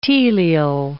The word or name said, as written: telial